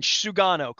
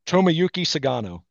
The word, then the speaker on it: Tomoyuki Sugano: If pronounce his name as best you can, Sugano, correct? – Yes. – Okay. – Tomoyuki Sugano.